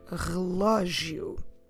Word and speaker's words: relógio: relógio. Relógio. The stress is on the lo, because we have an accent there. Como se escreve relógio? R-E-L-O. Com acento. G-I-O. Relógio.